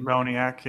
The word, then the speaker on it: Moniak: Noah Syndergaard to the Phillies from the Angels for Mickey Moniak. Is that how you say it? – Moniak, yeah.